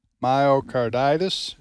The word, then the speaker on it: myocarditis: myocarditis, is that the way you pronounce it? – It is.